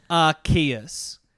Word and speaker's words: Arceus: It's pronounced Arceus. But in a couple of previous things, it was pronounced Arceus.